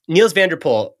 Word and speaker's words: Nils van der Poel: And you use a great example of this speed skater, Nils van der Poel. Is that how you say his name? – Yeah, Nils van der Poel, you got that right.